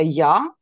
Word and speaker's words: ja: So I love the way you in South Africa, you say ja, which is exactly how we pronounce yes as well in Norway. – Okay. – We write it J-A and we say ja.